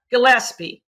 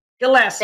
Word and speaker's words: Gillespie: Gillespie. Gillespie.